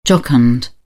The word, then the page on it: jocund: British English